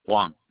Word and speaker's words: Juan: Juan. My first name is Juan. – Can you spell that for me? – J-U-A-N.